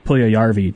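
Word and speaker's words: Puljujärvi: Sorry, I saw people talking about pronunciations on Twitter today, so I really want to make sure I get four syllables when I say Puljujarvi, just for Guy Flaming specifically.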